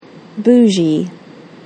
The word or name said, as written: bougie